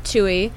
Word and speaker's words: Tui: I recently looked her up, and it turns out the correct way to pronounce her name is Tui.